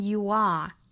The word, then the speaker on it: you are: you'll, 如果不缩约就是读成 you will, you'll never, 注意尾部不要卷舌，不是 never be more than you are now, more, 元音单元音，后面 than you are, you 和 are 连读，读成 you are, 这个时候就不要缩约了，因为它是处于这一句话的句尾，就不能够缩约了，不能读成 you, 应该读成分开的 you are, 并且 are 这个虚词要做重读 you are now, you'll never be more than you are now.